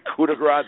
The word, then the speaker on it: coup de grace: You know, it's there to woo you, to let you bring your guard down so that the coup de grace can be put out there, so to speak. Did I say that right? – Yeah, sounded good to me. – Coup de grace, that was right.